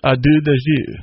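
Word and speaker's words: à deux de jeu: Deuce, the term, comes from the French phrase, a deux de jeu. I don't know if I said that right.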